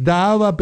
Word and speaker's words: Dahava: Daava Petroleum. Daava se escribe D-A-H-A-V-A Petroleum.